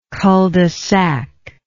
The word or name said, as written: cul de sac